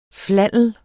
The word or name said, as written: flannel